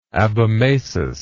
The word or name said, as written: abomasus